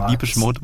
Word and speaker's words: Depeche Mode: Guck mal, du sprichst auch richtig aus, ne? Ich schreibe Depeche Mode, Depeche Mode. – Habe ich richtig gesagt, oder? – Du hast richtig gesagt.